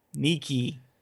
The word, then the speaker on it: Nike: In the classical era, and is now pronounced a bit like Nike. N-E-E-K-E. Nike.